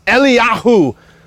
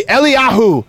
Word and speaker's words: Eliyahu: His name in Hebrew, if you're going to pronounce it, is Eliyahu. Say Eliyahu. – Eliyahu.